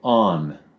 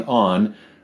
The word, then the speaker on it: Aun: So there was this king ruling in Uppsala, and his name was King On. Hopefully I'm saying that right. King On, A-U-N, King On.